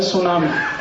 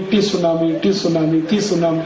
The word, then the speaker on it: tsunami: अंग्रेजी में एक शब्द है सुनामी, कैसे लिखते हैं? TSUNAMI। बोलते क्यों नहीं? टी सुनामी। कहते जी टी साइलेंट है, तो लगाया क्यों, हटा दो उसको।